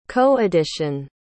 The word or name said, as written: co-edition